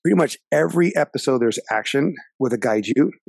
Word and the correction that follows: kaiju: Yeah. I'm saying it right, right? Am I saying it right? – It's kaiju. – Kaiju. Yeah.